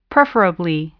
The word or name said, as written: preferably